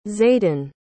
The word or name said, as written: zayden